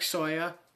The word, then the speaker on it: Sawyer: So if you type in Derek Sawyer, don't forget the C in my name because you will not find me.